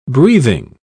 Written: breathing

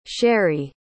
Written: Sherri

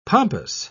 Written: pompous

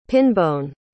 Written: pinbone

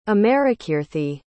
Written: amarakeerthi

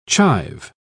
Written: chive

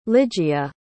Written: ligia

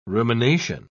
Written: rumination

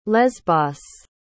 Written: lesbos

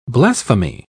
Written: blasphemy

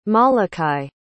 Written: molokai

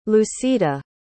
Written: lucita

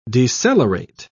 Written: decelerate

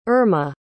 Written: irma

